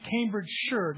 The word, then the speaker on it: Cambridgeshire: I practiced that all week, Cambridgeshire, not Shire, though it's spelled like that, it's sure for those of us from the motherland.